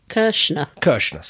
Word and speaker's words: Kirshner: M-I-A-K-I-R-S-H-N-E-R. – Kirshner. – Sorry. Kirshner.